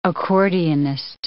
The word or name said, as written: accordionist